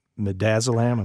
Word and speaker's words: midazolam: It's midazolam.